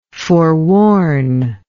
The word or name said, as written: forewarningly